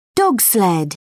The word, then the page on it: dogsled: British English